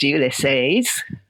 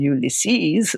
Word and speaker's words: Ulysses: which they pronounced Ulysses rather than Ulysses, as I had always done, was never going to catch on, they said.